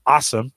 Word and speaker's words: Alxium: Also got contacted by this company called Awesome. It's how you pronounce it, but it looks like A-L-X-I-U-M. They say pronounced awesome.